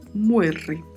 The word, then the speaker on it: muere: Particularidades da pronúncia, mais uma vez, a letra E com o som fechado, né, de E, e o R com o som vibrato. Então pronunciamos muere.